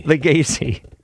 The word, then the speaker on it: Lagasse: Lagasse.